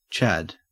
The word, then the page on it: chad: Australian English